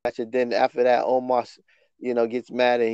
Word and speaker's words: Omos: I don't Anyway, Bobby Lashley and Omos had an arm wrestling match. – Is that how you say his name, or do you say it the way Bobby said it? I, Amos.